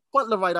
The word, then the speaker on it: Fauntleroy: Fauntleroy, that's it.